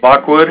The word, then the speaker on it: Lockwood: My name is Stephen Lockwood. – Stephen and Lockwood, could you please Lockwood. – Spell it for me? – L-O-C-K-W-O-O-D.